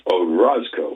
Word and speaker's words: Orozco: This is by Christian Oroz, I guess it's pronounced Orozco, O-R-O-Z-C-O, March 22nd, 2026.